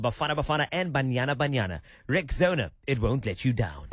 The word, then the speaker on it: Rexona: Stepping forward with a bold new name, it's pronounced Rexona.